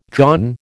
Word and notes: dawn: American English

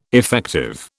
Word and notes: effective: British English

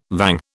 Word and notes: vang: British English

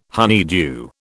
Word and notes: honeydew: British English

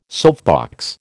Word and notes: soapbox: American English

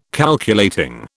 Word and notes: Calculating: British English

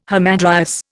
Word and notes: hamadryas: Indian

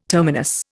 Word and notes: terminus: Indian

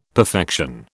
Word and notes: perfection: British English

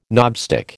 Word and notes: knobstick: American English

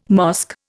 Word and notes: mosque: Indian

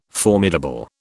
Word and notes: formidable: British English

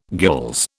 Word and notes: gules: British English

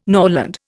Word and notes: norland: Indian

